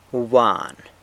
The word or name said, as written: wbwan